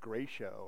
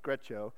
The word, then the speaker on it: Greccio: I think it's called Greccio. I think that's how you pronounce it, Greccio. And I actually went on YouTube and found someone pronouncing the word so that I could tell you it this morning and sound smarter than, because I was pronouncing it like Gratio or something, but no, it's Greccio.